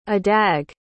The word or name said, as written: adag